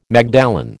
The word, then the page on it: magdalen: American English